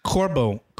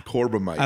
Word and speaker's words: Corbomite: Season 1, Episode 11, The Carbamite Maneuver. So grab yourself a drink. – Corbomite. – Corbomite? Is that how it's pronounced? – Yeah. – Corbomite.